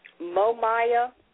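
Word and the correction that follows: Mumia: Oh, his name is Momia. Momia Abu-Jamal.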